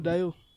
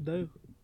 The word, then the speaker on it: Dalot: Diogo Dalot. Pronounce vizuri, Dalot.